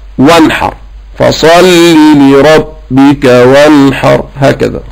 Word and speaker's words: فصلي: صلي ده مبني على حذف حرف العلة اللي هو كان الياء، وكمان مش مرسوم في المصحف، فتقول فصلي لربك. كذلك ما تخطفهاش ان هي ما تنطقهاش، انطق كسرة بس، فتقول فصلي لربك، فصلي لربك، واحرص على الكسر، الكسرات مهمة جدا.